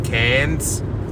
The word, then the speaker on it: Cairns: I'm also saying Cairns. – Cairns is good. Good pronunciation.